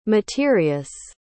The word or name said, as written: materious